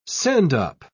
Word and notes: sendup: British English

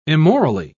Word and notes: immorally: British English

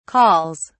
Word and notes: calls: British English

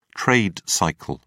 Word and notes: trade cycle: British English

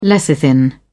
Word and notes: lecithin: British English

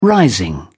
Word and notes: rising: British English

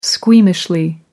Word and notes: squeamishly: American English

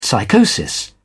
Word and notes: psychosis: British English

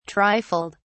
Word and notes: trifled: British English

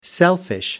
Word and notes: selfish: American English

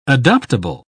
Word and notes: adoptable: British English

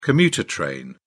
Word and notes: commuter train: British English

